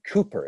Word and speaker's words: Cowper: And while he's there, he becomes friends, we'll talk about this in a little bit, with a very famous poet named William Cooper. It looks like Cowper, but it's pronounced Cooper.